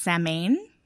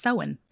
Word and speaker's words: Samhain: Uh, this tradition comes from the Celtic festival of Samhain, and it looks like it would be pronounced Samhain, but it's actually pronounced Samhain.